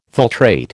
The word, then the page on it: filtrate: American English